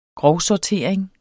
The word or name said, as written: grovsortering